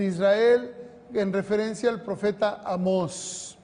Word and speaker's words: Amós: No, Amós. Tiene un acento en la O.